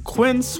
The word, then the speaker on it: Quince: Today Explained support today comes from Quince, which rhymes with since but is spelt with a Q-U.